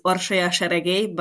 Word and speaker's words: Orsolya Seregely: Orsi, could you do it for me, please? – Yes, hello everybody. My full name is Orsia Sheregey, but as Jakub said, I also not really expecting people to pronounce it quite well, so Orsi is quite fine.